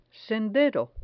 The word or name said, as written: sendero